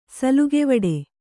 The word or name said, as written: ಸುಲಿಗೆವಡೆ